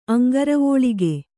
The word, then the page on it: ಅಂಘ್ರಿವಲ್ಲಿಕೆ: kannada